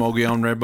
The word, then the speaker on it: Mogollon: This was a tricky word to pronounce because it's spelled Mogollon. So when I first saw it, I was like, Mogollon? – Mongolian? – No, it's like Mogollon. What the fuck's Mogollon?